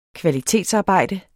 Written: Kvalitetsarbejde

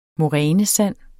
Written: morænesand

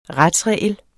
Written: retsregel

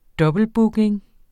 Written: dobbeltbooking

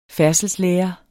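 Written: færdselsundervisning

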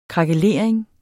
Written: krakelering

